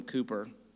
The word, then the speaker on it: Cowper: Well, the trick is to learn the lesson of the old hymn by William Cooper. It's spelled Cowper, pronounced Cooper, whose life has been described by one historian as one long accumulation of pain.